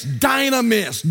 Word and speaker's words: dynamis: Well, that word power there in Acts 4:33 is the Greek word dynamis, D-Y-N-A-M-I-S, dynamis, dynamis.